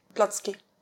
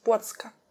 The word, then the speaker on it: Błocka: Blotsky. – Blotsky. Mm-hmm. And how do I say it in Polish? – Błocka.